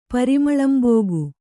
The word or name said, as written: ಪರಿಮಳಂಬೋಗು